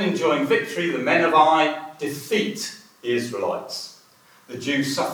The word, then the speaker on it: Ai: It's pronounced Ai, it's A-I.